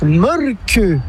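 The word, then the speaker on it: Mölkky: Inventé en Finlande en 1996, ce jeu de quilles s'écrit M-O-T-R-E-M-A-L-K-K-Y. – Ça s'écrit molki, mais ça se prononce molke. – Le molki. – Plutôt un E qu'un E, mais pas un U. Comme ça fait molque.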